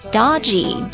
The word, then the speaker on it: dodgy: 下一个单词换眼一看有点像狗那个单词 dog，这单词读作 dodgy, D-O-D-G-Y, D-O-D-G-Y, 形容词表示狡猾的、有毛病的或者危险的等等意思。比如说他这人呢有点不老实。